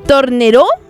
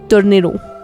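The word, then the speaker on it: Tornerò: Tornero. Tornero. Es que tiene el acento al revés. Según yo es más grave. Tornero.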